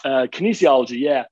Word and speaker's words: kinesiology: Kinesiology. Is that? – Pretty close. Kinesiology. Yeah.